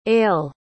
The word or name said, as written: aillt